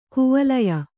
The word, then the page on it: ಕೈವಲ್ಯ: kaivalya